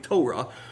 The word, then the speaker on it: Torah: Now, this, but those who knew the Torah, which I've, for the first time, I have heard it pronounced correctly as I was listening to messages this week, Torah.